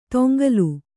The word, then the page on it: ತೊಂಗಲು: toŋgalu; kannada